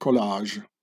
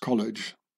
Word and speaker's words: collage: In French it's called collage. In English it's been pronounced college.